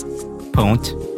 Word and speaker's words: pwnt: How do you pronounce this? – Pwnt.